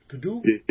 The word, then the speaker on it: Gadoom: You sent me this Levy Brothers and this tune, Gadoom. Gadoom? – Yeah, yes. – Did I pronounce it right? – Yep. – Okay. – That's exactly right.